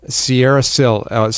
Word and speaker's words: Sierrasil: Sierrasil, spelled with an S, S-I-E-R-R-A-S-I-L. So Sierra, like the mountains. – Sierra, yeah. – And then S-I-L, which reflects the mineral structure.